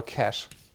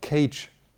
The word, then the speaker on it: cache: And this is, of course, pronounced just like our cash. Please do not pronounce the cash, the quick memory, as cage.